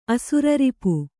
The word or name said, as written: ಅಸುರರಿಪು